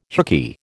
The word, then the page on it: hookey: American English